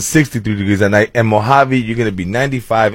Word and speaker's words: Mojave: What do you think rhymes with Mojave? – Wasabi? – Hey! There you go.